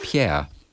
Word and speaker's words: Pierre: Das waren Ifis und Pire. Und die heißen Nämlich Yves, Yves und Pierre.